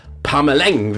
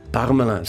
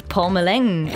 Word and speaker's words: Parmelin: Die meisten lassen dann das Altone weg und sagen den Namen, wie wenn es ein Finn wäre, mit Ä geschrieben, Parmelin. Und wehe, unser neuer Bundesrat Bundesrat geht künftig mal auf Staatsbesuch auf Deutschland. Dort wird aus «ein» nicht ein, sondern «eng», wie «peng», «parmeleng». Sogar noch schlimmer, Pameleng,